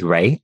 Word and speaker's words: Rae: And my surname is Ray, RAE.